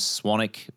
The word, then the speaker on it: Swanwick: I'm on Instagram at James Swanick. My last name is spelled with a W, so S-W-A-N-W-I-C-K.